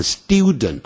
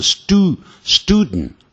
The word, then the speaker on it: student: 所以啊，这里的英文里的发音像 student，学生呢、啊、本来应该慢慢念叫 student，英式念法念 student 啊，但美式没有那个一的音，也就是 student，